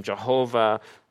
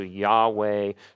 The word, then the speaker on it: Yahweh: And so some people traditionally in English-speaking language have used the word or the name Jehovah. More modern, we feel like it's closer to Yahweh.